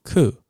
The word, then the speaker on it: coup: C O U P coup 啊，这个 P 是不发音的哦，念 coup 很酷的酷这样 coup。